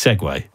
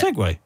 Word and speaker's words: segue: There's the word that's spelled S-E-G-U-E. – S-E-G-U-E. – I would hope you'd be... Is that pronounced? – Seg. – Segway, Seg, Seg, or Seg-U. – That's Seg. – It's Segway. – Is it Segway? – Yeah. – Is it's Segway. S-E-G-U-E. – S-E-G-U-E.